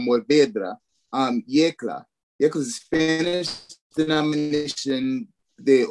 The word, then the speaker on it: Yecla: YECLA.